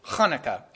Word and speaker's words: Hanukkah: But the way we hear about it in modern times is Hanukkah, and that is the correct pronunciation.